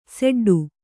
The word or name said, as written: ಸಡ್ಡು